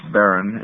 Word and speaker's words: Barran: Let me start with Ms. Barran.